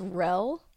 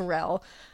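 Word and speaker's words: Rehle: I believe it's pronounced Rell. It's R-E-H-L-E. He's of German descent. – Okay. – So I believe it's Rell.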